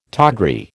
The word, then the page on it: tawdry: American English